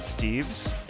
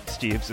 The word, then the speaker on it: Steeves: Uh, you can find me on Twitter at, at David Steeves. Steeves is spelled S T E E V as in Verka Serduchka, E S.